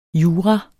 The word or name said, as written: jura